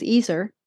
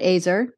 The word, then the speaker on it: Ezer: So the original word for woman at creation is Ezer. It's it, in, in our translations, it's helper or helpmeet. Mm-hmm. That word, you can also pronounce it Azer both ways, but that word means rescuing strong power.